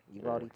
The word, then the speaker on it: Uvalde: Is it Uvalde? – Uvalde yeah.